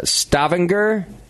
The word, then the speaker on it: Stavanger: The accent's on the second half. – Stavanger. Yeah. I think that is closer. Stavanger, Norway.